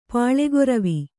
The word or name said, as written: ಪಾಲೆಗೊರವಿ